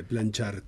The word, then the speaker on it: Planchart: Como plancha, como plancha y termina art. – Art. – Como arte, planchart.